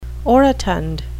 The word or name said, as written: orotund